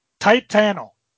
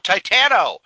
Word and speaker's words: Titano: Tell the folks about Tit Anno. – Huh? – Oh, I'm sorry. I said that wrong. Titano. – Oh, Titano.